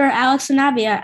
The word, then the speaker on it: Sanabia: We have former Marlins pitcher Alex Sanabia.